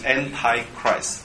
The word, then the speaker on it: Antichrist: 敌基督加上一个字叫 anti，Antichristos，anti 重音在第二音节啊，这是希腊文，anti，anti 啊，antichristos。如果是英文也是从希腊文这样直接翻过来哈，英文就是 Antichrist，好，大把重音翻成第三音节 Antichrist，Christ 是基督嘛，Anti 他把它念成 Anti，好，其实英文叫做 Antichrist，可是希腊文叫 Antichristos，啊，比较难一点啦，比较绕口哈，没关系啊，反正这大家记得就好。